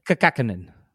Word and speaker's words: Kokkonen: Kokkonen.